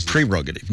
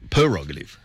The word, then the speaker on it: prerogative: Because it's actually prerogative, not prerogative.